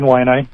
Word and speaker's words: Waianae: Yeah, but we are in Waianae? – We're in Waianae.